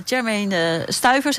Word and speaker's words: Jermaine Stuyver: Want jij bent Jermaine Stuyver, zeg ik het goed? – Ja, klopt helemaal.